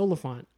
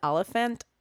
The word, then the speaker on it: Olyphant: And they let him do his, yeah, it's Olyphant. – I always read it as Oliphant. But I like it now that I know. I like it better now that I know how to pronounce it.